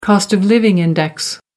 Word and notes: cost-of-living index: American English

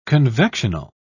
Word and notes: convectional: British English

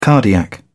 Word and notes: cardiac: British English

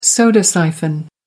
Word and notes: soda siphon: American English

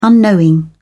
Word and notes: unknowing: British English

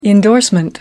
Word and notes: indorsement: American English

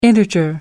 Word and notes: integer: American English

